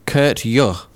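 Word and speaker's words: Køge: Actually, I think you pronounce the team who Arsenal are playing as Kurt Juh. So, it's nothing how it's spelt, according to that.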